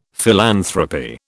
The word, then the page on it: philanthropy: British English